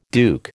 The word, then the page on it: duke: American English